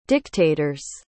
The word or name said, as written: dictator's